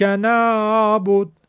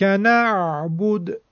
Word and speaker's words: نعبد: نه اینکه بگیم نعبد ایاک نعبد که اصلا عین نگفتیم انگار، یا اینکه برعکس بگیم ایاک نعبد که این هم خیلی سخته، بلکه ایاک نعبد اینطور تلفظ کنیم.